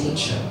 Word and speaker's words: Nietzsche: Friends are always telling me that is way too niche of a joke to be said on stage. And I'm always patiently telling them that it's actually pronounced Nietzsche.